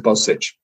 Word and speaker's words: Bozsik: But just to go back to the, the, the match, just to round it off, that um, in the second half, Bozsik, is that how you pronounce it? – Scored. Yeah, Joseph Bozsik.